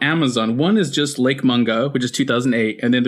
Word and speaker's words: Mungo: They say mango? – No. I think it's... – Oh, God. – Did you say mango? – What? We're okay, we don't know how to pronounce the name. Okay, all right. – Mungo. Lake Mungo.